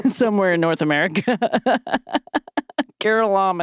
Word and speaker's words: Curelom: I'm trying to go off the pronunciation here. Curelom and Cumom. How do you spell this shit? C U R E L O M. That's the first word. And Kurlam not according to this pronunciation.